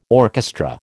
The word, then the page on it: orchestra: American English